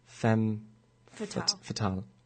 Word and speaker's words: Femme Fatale: Today started off with Femme Fatale.